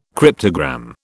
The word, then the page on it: cryptogram: British English